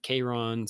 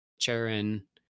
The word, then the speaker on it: Charon: Like some people say it's Charon, some people say it's Charon, some people say that like the traditional Greek pronunciation, if you want to be the most accurate, would be like Charon, because I think it would be K in Greek, and K's were like silent.